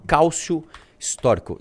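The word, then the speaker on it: calcio storico: É um esporte que tem na Itália, se chama cálcio histórico. É, é, é cálcio, do jeito que se escreve mesmo, cálcio. Agora o histórico é com S mudo. Histórico. Cálcio histórico.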